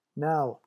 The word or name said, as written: now